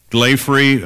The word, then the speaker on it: Glaphre: Glaffery, I think that's the correct way of pronouncing it. Her name was spelled G-L-A-P-H-R-E, Glaffery.